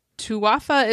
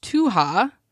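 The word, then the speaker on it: Tuatha: Um, she said "Tuwafa is pronounced Tuha.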